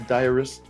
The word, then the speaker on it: diarist: The estate of famous diarrheaist, it should be diarist. What do you call it when you write a diary and they put it out? A diarist? Diarist.